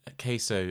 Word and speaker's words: Caeso: C-A-E-S-O. – C-A-E-S... Queso, yeah. – Queso? – Yeah. – Can you say so? But it wouldn't be, would it? – It's a hard C.